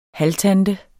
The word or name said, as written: halvtante